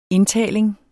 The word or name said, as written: indtaling